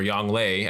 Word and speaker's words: Yongle: So let's talk about specifically the new leaders and the possibilities that we can have with the unifier Yongle or Yongle, as it's actually pronounced, just a FYI, mm-hmm, mm-hmm.